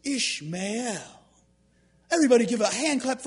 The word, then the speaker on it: Ishmael: His name is Ishmael. Ishmael. It's pronounced Yishmael.